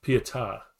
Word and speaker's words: Pietà: The synopsis, the story, well, the cover shows Batman holding Sergeant Rock in the I always forget the pronunciation. Pieté, I believe it is. Pose. – The Pietà.